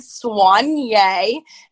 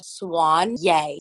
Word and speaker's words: Soigné: But it's just, so how you say it is Soigné, and the term means dressed very elegantly and well-groomed. And it literally, let's just like clarify, we'll break it down. Guys, it's called Soigné.